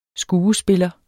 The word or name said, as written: skuespiller